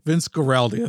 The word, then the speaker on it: Vince Guaraldi: I think it is not only a fantastic Christmas record, but actually a brilliant jazz piece by the Vince Gagliotti Trio. – I'm glad you said that, not me. – I think it's Vince Guaraldi. I think it's pronounced.